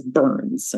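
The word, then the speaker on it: BHRN: which for the rest of this call, I'm going to pronounce as BURNS. Okay, that's how you say that acronym, BURN.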